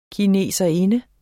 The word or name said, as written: kineserinde